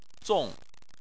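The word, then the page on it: zong: tone 4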